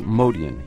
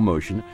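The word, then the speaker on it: Motian: Well, it is Modian. He is of Armenian extraction, so the correct pronunciation is, in fact, Modian. Mm-hmm. When I first heard him in the early 60s with Bill Evans, he was Paul Modian. Mm-hmm. But as the years passed, people who didn't know how to pronounce the name called him Motion, and I guess he liked it because it stuck. He is now Paul Motion.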